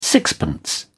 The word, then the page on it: sixpence: British English